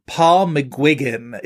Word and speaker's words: McGuigan: But anyway, we will be talking about 2017's Film Stars Don't Die in Liverpool, directed by Paul McGuigan, is how they pronounced it on the red carpet at TIFF, so that is how I will pronounce it too. It is not how I would have pronounced it before I heard that.